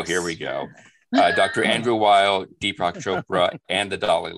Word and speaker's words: Weil: Weil.